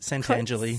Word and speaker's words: Santangeli: Pretty close, Santangeli.